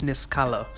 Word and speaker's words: Niskala: Yes, again, it's actually spelled Niskala, but it's pronounced Niskala.